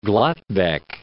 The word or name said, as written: Gladbeck